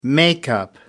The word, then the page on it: makeup: British English